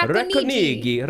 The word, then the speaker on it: Racconigi: Racconigi. I'm sorry, I don't know how to If I tried to do an Italian accent to pronounce it, it would not go well. – No. Racconigi. – Racconigi.